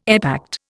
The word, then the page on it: epact: Indian